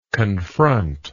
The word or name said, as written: confronter